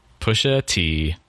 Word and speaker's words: Pusha T: Pusha T. The letter T, Pusha not like the drink.